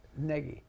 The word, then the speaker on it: Gnagy: Negi's spelt with a G. – Joe. – G-N-A-G-Y, Negi.